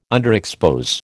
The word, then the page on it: underexpose: American English